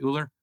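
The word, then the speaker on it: Ooler: Is that how you pronounce it, Uller? – Yep. Uller like cooler without a C.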